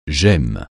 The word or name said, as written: gemme